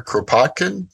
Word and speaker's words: Kropotkin: Pleasure to have you on here to talk about Peter Kropotkin.